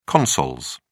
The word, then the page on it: consols: British English